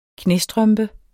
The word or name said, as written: knæstrømpe